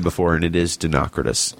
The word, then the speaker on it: Dinocrates: Dinocrates. That's it. That's how to say it. Dinocrates.